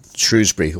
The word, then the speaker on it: Shrewsbury: That's what I use, There's also the latent image in Shrewsbury or Shrewsbury, depending on which way you want to say it.